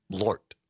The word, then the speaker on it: Malort: But Carol says it's one syllable. Malort.